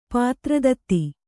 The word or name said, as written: ಪಾತ್ರದತ್ತಿ